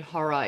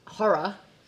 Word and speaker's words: horror: Okay, really quickly, when Americans say the word horror, it's absolutely two syllables because otherwise it's. – What do you say? – Horror.